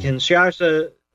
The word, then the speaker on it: Kinshasa: In Kinshasa, Zaire. – Yeah, you pronounced that right.